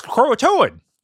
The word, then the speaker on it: Croatoan: It's Croatoan. Yeah. – Yeah, so it is misspelled. – C-R-O-A-T-O-A-N is the way it is spelled, like, historically, and you're right, yeah.